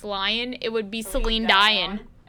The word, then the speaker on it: Dion: It looks like it would rhyme with lion, but it doesn't. If Dion rhymed with lion, it would be Celine Dion.